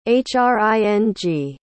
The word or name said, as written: hring